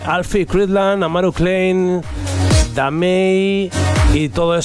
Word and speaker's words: Damae: Los responsables de esta producción son Alfie Cridland, Amaru Klein y Damei. ¿vale? Que es como se pronuncia correctamente. Nosotros decimos Damae, que es como lo pronunciamos en español, pero en su saludo lo escucharás.